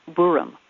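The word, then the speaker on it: Boraem: I guess it was supposed to be pronounced Borum. But they never succeeded, and they'd be horrified to know that even their descendants pronounce it wrong today.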